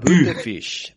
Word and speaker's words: Bütefisch: Es Bütefisch, con U. – Bütefisch, vale, de acuerdo.